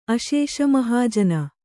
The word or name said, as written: ಅಶೇಷಮಹಾಜನ